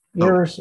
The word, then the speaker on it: ERIS: So let's talk about ARIS, E-R-I-S. Did I say it correctly? – Yeah, ARIS, oh. ARIS, yep.